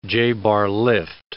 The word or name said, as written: J-bar lift